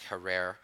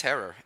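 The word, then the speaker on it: Herer: Now, Jack always said, a lot of people say Jack Herrer. It's Herrer. It rhymes with terror.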